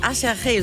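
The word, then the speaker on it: Aserejé: Las Ketchup Song von einer Combo namens Asherje, so wird die ausgesprochen, aber sieht aus wie Achherje, so kann man sich das auf jeden Fall gut merken.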